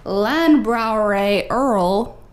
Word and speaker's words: Landbrauerei Erl: Yeah, so this is a German Helles style beer brewed at Landbrauerei Erl. I'm sure that's exactly how it's supposed to be pronounced. – That was spot on pronunciation.